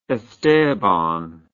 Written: esteban

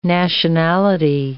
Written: nationality

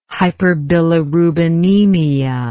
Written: hyperbilirubinemia

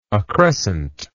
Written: accrescent

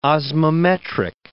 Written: osmometric

